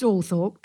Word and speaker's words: Althorp: But we did have, I mean, the most amazing weekend, because your house, or it is pronounced Althorpe, correct? – Yes.